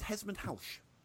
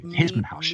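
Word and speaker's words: Hesmondhalgh: Well, obviously, this being English, you know, it's spelled Hesmondhalg, but it's pronounced Smith. apparently, it's pronounced Hesmondhalsch. – Halsch. Okay, I would not have guessed that. Okay, thank you. Hesmondhalsch.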